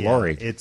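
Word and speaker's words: Lauri: Oh, no, this is spelled Laori, Marketing. Dave. It's L-A-U-R-I. That's Laori.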